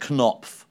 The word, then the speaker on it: Knopf: This is the people at Knopf. – Yep. – Rog, I believe is yep. – how it's pronounced. – Yep. – They've asked us, Knopf.